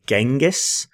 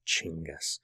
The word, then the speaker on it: Chinggis: It looks like it should be Genghis, and you'll often hear the word, the name pronounced as Genghis, but it's more correct pronunciation to be something like Chinggis.